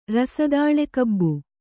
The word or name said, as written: ರಸದಾಳೆಕಬ್ಬು